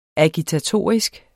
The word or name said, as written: agitatorisk